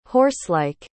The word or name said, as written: horselike